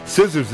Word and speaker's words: scissors: Scissors is a funny word, isn't it? Because the C is silent? – Yeah.